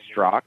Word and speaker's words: Straka: On Twitter, it's my name, at Brandon Strzok. And my last name is spelled S-T-R-A-K-A.